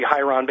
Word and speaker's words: hieron: One is, pronounced in English, would be hieron, basically H-E-I-R-O-N.